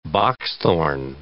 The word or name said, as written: boxthorn